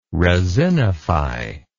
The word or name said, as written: resinify